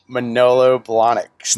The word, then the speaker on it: Manolo Blahniks: or Manolo Blahniks. Did I say that right? Is that it? Is that the right shoe? – Manolo Blahniks.